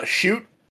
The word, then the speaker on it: Chute: Chute? It's spelled exactly like it sounds. Shoot. Chute.